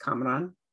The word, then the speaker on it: Kamran: And we watched episode two on my friend Cameron's birthday, which his name should be pronounced Kamran, like the character on the show. It's been totally Americanized.